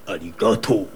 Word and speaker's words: arigato: Like we say arigato.